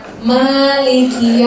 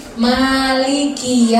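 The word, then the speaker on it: Maliki (مَالِكِ): Biasanya di Maliki Ki-nya tuh kadang ada yang bacanya hilang. Jadi Malik Yaumiddin, enggak jelas. Padahal di situ ada kaf berkasroh, kaf yang ada harokat kasrohnya. Jadi Maliki Yaumiddin, ki-nya harus jelas.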